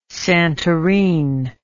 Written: Santorin

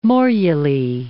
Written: morulae

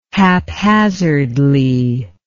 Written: haphazardly

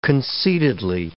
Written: concededly

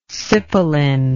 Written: cipolin

